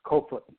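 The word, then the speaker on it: Koevoet: So Crowbar in Afrikaans is pronounced Kofoot.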